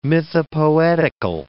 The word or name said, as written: mythopoetical